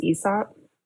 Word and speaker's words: Aesop: Aesop, which started as an Australian hair care, like salon hair care brand. – You know it's pronounced Aesop?